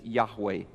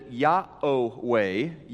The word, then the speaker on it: Yahweh: In the 3rd century, there was a Christian leader named Bishop Clement of Alexandria, and he pronounced it Yahweh, 3rd century, so that's getting back there pretty far.